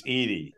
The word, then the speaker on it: Edey: And actually, before the season, I thought he'd be the national player of the year before uh, Zach Eddy from Purdue started playing like a madman. Uh, but you got to get you know, Tyler to pronounce it right. It's Edey.